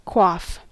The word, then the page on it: quaff: American English; cot-caught merger